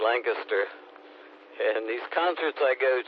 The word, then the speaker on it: Lancaster: I always think of the word canister. It's like Lancaster, canister. Same ending on it anyway.